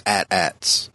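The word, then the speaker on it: AT-AT: It has gone as far as Pablo Hidalgo, head of Lucasfilm Story Group, came out and said, yes, it's pronounced the same way as you would pronounce hat, hat. It's not H-A-A-T, H-A-A-T. It's hat, hat. It's at, at. And the ones that we saw in Rogue One is at, at.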